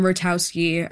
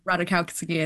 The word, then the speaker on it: Ratajkowski: There's M. Ratowski. I'm mispronouncing her name, so sorry. But Ratajkowski, I think it is.